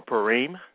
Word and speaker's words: Purim: Well, this program today, we will discuss Purim, or Purim, that's how you pronounce it in Hebrew. That's the Hebrew way to pronounce it.